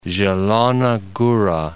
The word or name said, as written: Zielona Gora